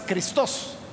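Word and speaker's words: Cristos (Χριστός): O sea, en griego no es Cristo, es Cristos. Ahí lleva la fuerza en la segunda sílaba.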